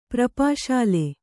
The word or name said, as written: ಪ್ರಪಾಶಾಲೆ